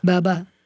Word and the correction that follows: Barbara: Baba Walters. – Barbara.